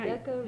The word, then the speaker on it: Rebecca: Rebecca Ruth Schaefer. – And spell it, please. – The whole name, R-E-B-E-C-C-A, Ruth, R-U-T-H, Schaefer, S-C-H-A-E-F-E-R.